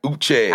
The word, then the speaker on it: Uche: Uche. – I fucked it up. Uche. – Yeah, yeah, yeah. – Why would you... – You think of Chi, Uchi.